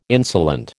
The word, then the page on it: insolent: American English